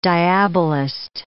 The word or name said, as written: diabolist